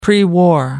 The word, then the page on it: pre-war: American English